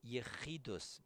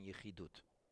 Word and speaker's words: Yechidus: However, to understand this, I want to first discuss what Yechidus means. In Sephardic pronunciation, Yechidut.